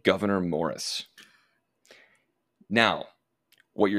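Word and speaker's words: Gouverneur: His first name was just Governor. It is spelled a little bit differently, but Governor Morris. I've heard it pronounced a couple of different ways, but Governor is the one that I like best.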